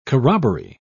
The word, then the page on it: corroboree: British English